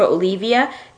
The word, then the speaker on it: Olevia: If you want to search for Olivia, it's spelled O-L-E-V-I-A.